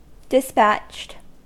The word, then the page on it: dispatched: American English